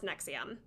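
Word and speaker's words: NXIVM: So, Nexium is spelled N-X-I-V-M. I don't know why, but it's pronounced Nexium.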